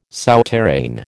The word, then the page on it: souterrain: American English